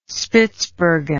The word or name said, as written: Spitzbergen